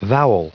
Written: vowel

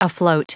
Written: afloat

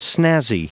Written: snazzy

